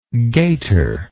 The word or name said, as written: gater